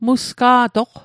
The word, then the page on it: musga'toq: Mi'gmaq